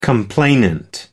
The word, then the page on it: complainant: American English